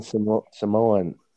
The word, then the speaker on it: Samoa: Maybe he's American Samoan. And I guess we all say that wrong. It's Samoa.